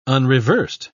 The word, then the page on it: unreversed: British English